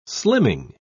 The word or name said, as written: slimming